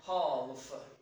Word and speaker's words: half: So in that case, you try to make it like Turkish, like half, right? Logical, right? But in reality, sometimes the L is silent.